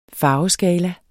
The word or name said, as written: farveskala